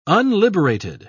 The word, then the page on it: unliberated: British English